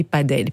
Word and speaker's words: padel: Et donc, les amateurs de précision prononciatoire différencient paddle et padel, puisque...